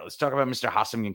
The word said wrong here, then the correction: Ha-Seong: Kim. Uh, I probably butchered that name, so let me actually try and get that pronunciation. So Ha-Seong Kim. All right, I didn't do so bad. Ha-Seong Kim.